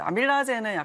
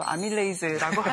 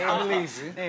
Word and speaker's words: 아밀레이즈: 네, 이제 아밀라제는 약간 좀 구식 발음이고 아밀레이즈라고 하는데. – 아밀레이즈.